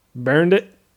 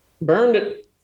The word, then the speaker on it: Behrndt: Spell it for me. – B-E-H-R-N-D-T. – Burned it. – Burned it.